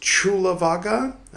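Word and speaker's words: Cullavagga: Who commented, letting me know that it's pronounced Chulavaga. I really wish that the Polytech Society had chosen to spell it Chulavaga rather than spelling it Kulavaga, making me sound like a fool.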